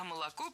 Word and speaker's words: молоко: А молоко произносим «ашно», а пишем «ошно».